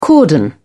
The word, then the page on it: cordon: British English